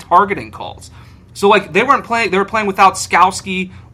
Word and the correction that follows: Skalski: right? I think that's how you pronounce it. – Skalski? Yeah, Skalski.